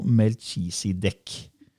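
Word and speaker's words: Melchizedek: Men nei, han, Drunvalo, rett fram, det er U-N-V-A-L-O. Og Melchisidek er e e e M-E-L-C-H-I-Z-E-D-E-K. Drunvalo Melchisidek.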